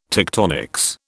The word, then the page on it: tectonics: British English